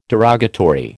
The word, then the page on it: derogatory: American English